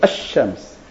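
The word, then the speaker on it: Ash-Shams (الشمس): Il est écrit, les Arabes écrivent le Lamed, mais ne le prononcent pas, et mettent à la place un Dagesh, dans la prononciation Ash-Shams.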